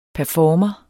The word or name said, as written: performer